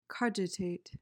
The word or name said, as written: cogitate